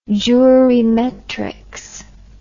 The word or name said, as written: jurimetrics